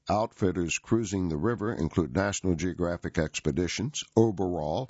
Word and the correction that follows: Oberoi: which is spelled O-B-E-R-O-L, or I'm sorry, Oberoi, excuse me, O-B-E-R-O-I, and Abercrombie and Kent.